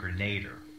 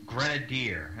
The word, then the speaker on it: Grenadier: First of all, I must say that for 20 years I've been pronouncing the name wrong because I've always said Iron Grenader. You know, so it's Grenadier, like the drink, Grenadine.